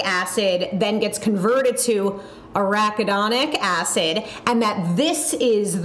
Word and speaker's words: arachidonic: how do I pronounce this? Arach, arachidon. Oh God. – arachidonic. – All right. Sorry. Thank you. Arachidonic.